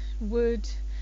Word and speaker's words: would: You've got should, could, would, all of these O-U-L-D words, you don't pronounce the L. So it's not could I have a cup of coffee please, or would you mind helping me? It's could I have, would you mind? So it's like that L isn't there at all.